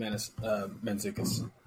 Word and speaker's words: Mantzoukas: Jason Mantzoukas. – Okay, Jason Mantzoukas. – Not Mana, just Mantzoukas. – Well, that's what I said the first time, Mantzoukas.